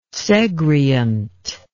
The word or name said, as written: segreant